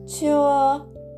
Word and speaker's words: 车: チュー」「チュー」これが「車」という文字の発音になりますなので、えー、我下中であの文字通り車から車を降ります。